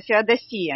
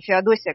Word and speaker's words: Феодосия: Опять же, я хочу обратить ваше внимание, что слово греческое по происхождению, и правильно его произносить именно Феодосия, а не Феодосия, как нам привычно.